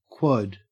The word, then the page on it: quad: Australian English